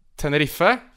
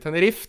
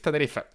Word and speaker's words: Tenerife: Og, kan du gjenta uttalen på det laget? – Tenerife. – Takk. – Ja, det er ikke Tenerife, Tenerife.